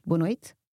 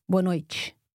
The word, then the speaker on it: boa noite: Do you remember how Rafael pronounced boa noite? He said boa noite, whereas I say boa noite with a soft T sound.